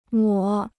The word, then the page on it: 抹: mā